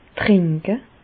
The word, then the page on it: trenke: Bas Rhin; Reichshoffen